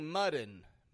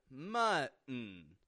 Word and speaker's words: mutton: Once again, notice I'm not saying mutton, mutton, nor am I saying mutton, mutton, but rather mutton. We're using the glottal T in the middle, folks. The glottal T. Uh-oh. Uh-uh. Mutton. Mutton. It's all about stopping that T and controlling your syllable stress.